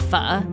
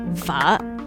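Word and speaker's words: phở: It's actually pronounced Pho, by the way. Say it with me. Pho.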